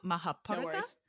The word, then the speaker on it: Mahabharata: Mahabharata.